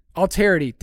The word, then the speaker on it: Alterity: One from Alterarity. Alterarity? How do I say this? – Alterity? – Alterity. Dang it, that's way better.